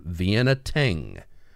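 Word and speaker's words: Vienna Teng: And she quotes the band Vienna Ting, and that is spelled T-E-N-G. And as she says her, like it's a single performer, but it's a band, Vienna like the city, Ting, T-E-N-G, a song called Hymn of Axiom.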